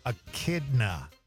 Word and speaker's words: echidna: He says it's pronounced echidna.